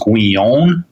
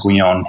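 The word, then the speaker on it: Guignon: And this little Hackett edition has an introduction by, I think his name is Charles Guyon, like G-U-I-G-N-O-N, I think is how his last name is spelled.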